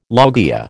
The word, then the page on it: loggia: American English